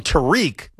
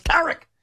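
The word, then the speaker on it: Tarik: And some people call him Tariq, but it's not pronounced Tariq. It's pronounced Tarek.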